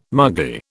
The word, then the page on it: muggy: British English